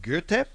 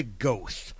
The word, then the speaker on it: Goethe: Johann Wolfgang von Goethe. See, notice how I pronounced that properly. I don't say Goethe.